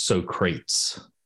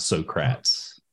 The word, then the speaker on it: Socrates: Well, before I get to that, I need to correct you on one thing, and I hate to nitpick, but the correct pronunciation is Socrates, not Socrates.